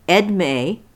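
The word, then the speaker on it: Edmée: Delafield, her actual name was, this is a very interesting name, Edme, E-D-M-E-E it's spelled, so it looks like it's French, Edme, Elizabeth Monica Dashwood.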